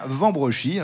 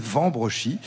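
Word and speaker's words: Wambrechies: Ça s'écrit avec un W au début, mais on dit bien Vambrechy. Hein. Souvent, on prononce W au début, surtout dans la région. Mais ici, c'est Vambrechy, à 7 km au nord de Lille.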